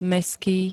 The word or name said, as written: mestský